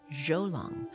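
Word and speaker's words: Zhou Long: And by the way, his first name is not J-O-E, it's spelled Z-H-O-U, Jolong.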